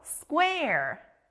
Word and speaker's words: square: 퀘 소리가 나는 또 하나의 단어가 있네요. 바로 네모, square예요. 여기도 중간에 퀘 소리가 나요. 우리가 창문이나 박스를 보면 네모나죠? 네모, 영어로 square.